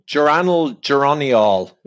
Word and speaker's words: geraniol: How do you say it again? Geraniol? – Oh, geraniol is how I pronounce it.